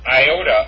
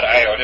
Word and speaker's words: iota: and so that and iota or Iode. It's not Iode. It's Iota. So this is pronounced Illyricum.